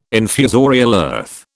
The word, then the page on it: infusorial earth: British English